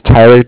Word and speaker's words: Tyler: Yeah, my name's Tyler, T-Y-L-E-R. T-Y-L-E-R. And yep. I'm with Bank of Cardiff. It's spelled C-A-R-D-I-F-F as in Frank.